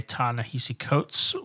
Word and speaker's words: Ta-Nehisi Coates: So before we get into Black Panther by Ta-Nehisi Coates, which we did confirm that is how you pronounce their name.